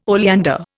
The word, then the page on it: oleander: Indian